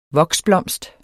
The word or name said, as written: voksblomst